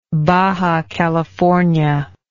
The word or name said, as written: baja california